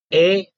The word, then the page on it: e: Sanskrit